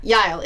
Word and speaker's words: Yael: And yeah, that is how you pronounce it. Yael. It is a Hebrew name.